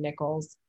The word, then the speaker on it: Nichols: It's M-A-K-A-I-L-A, and then Nichols, N-I-C-H-O-L-S.